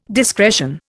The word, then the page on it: discretion: Indian